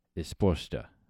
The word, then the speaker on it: Resposta: Frida just let me know that in Brazilian Portuguese, initial R's are pronounced like an English H. So this would be, it wouldn't be Resposta ao Tempo. It would be Resposta ao Tempo.